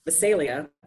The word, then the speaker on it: Visalia: Valencia. I didn't say that right. Visalia.